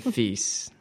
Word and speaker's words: Fiess: It's J-E-R-Y, Jerry with a J, mm-hmm. – And then Feese. It rhymes with geese, but it's spelled F-I-E-S-S.